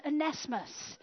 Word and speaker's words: Onesimus: Because it's spelt onesie, Simus. But his name was Onesimus.